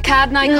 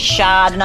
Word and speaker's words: Chardonnay: Would you like a Cardonnay, Kylie? – Kim, it's not Cardonnay. The correct pronunciation is Chardonnay. – Mum, it's French. The H is silent.